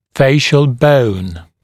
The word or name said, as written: facial bone